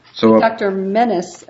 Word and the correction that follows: Menashe: am I saying his name right? – Menashe. – Menashe. Oh, I like Menace better.